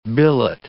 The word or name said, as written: billet